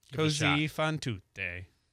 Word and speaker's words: Così fan tutte: And then the Mozart, I cannot say this correctly. – Così Fan Tutte. – Così Fan Tutte. – Perfect. Ah, perfect. Yes.